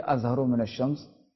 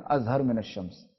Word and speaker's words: اظہر من الشمس: دیکھیں آپ نے یہ پڑھا دوستو یہ اظہرو من الشمس کے تلفظ ہوئی ہے مگر ہمارے کہیں اظہر من الشمس.